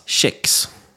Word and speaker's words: CEIX: Uttalas Chex. – Exakt, uttala sex, eller sex kan man ju också säga.